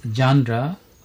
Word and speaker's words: genre: এরপরে শব্দটি হলো জানরা, GENRE। Pronunciation of this word is জানরা and the form is noun and the meaning is kind or sort or variety.